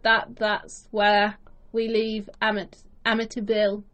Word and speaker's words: Amityville: To me, over here, I think we always hear is Amityville. – Yes. – But the actual American pronunciation, I believe, is Amityville.